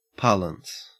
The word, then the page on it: parlance: Australian English